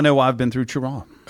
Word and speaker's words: Cheraw: Cheraw.